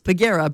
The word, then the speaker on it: Pagera: All you have to do is text the word Pagera, P-G-A-R-A, which when I first got to Prince George, that's how I thought you pronounced it, P-G-A-R-A. No, it's Pagera.